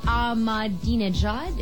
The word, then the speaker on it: Ahmadinejad: You beat out candidates including Iran's President Mahmoud Ahmadinejad.